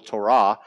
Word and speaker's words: Torah: Maybe you've heard it pronounced Torah, but in Hebrew, the emphasis is on the last syllable, Torah.